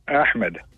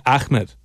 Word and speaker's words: Ahmed: It's A-H-M-E-D. – But you pronounce it Ahmed. – Ahmed. It's with an eh.